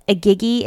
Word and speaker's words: Igigi: The Igigi, it's I-G-I-G-I.